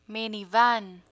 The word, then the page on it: minivan: Spanish